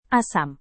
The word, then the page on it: asam: indonesian